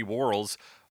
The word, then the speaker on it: Warhol's: Andy Warhol's... How is that pronunciation, Alex? Where are we on that? – Well, I think it's like Hull, as in Hulls.